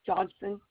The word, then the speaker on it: Johnson: Johnson, J-O-H-N-S-O-N.